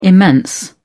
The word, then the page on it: immense: British English